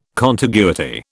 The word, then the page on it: contiguity: British English